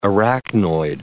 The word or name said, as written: arachnoid